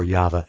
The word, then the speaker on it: Yahweh: and so we pronounce YHVH as YAHVAH, Yahweh, or Yahweh. That's how we pronounce that.